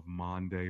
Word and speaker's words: Maundy: It's spelled M-A-U-N-D-Y, M-A-U-N-D-Y, Maundy, which actually is the Latin word for mandate or command, and this has to do with on Thursday of Passion Week when Jesus had the Last Supper.